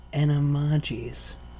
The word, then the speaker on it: Animagus: You want to know how they say it in the Audible book? – What? – Animagus.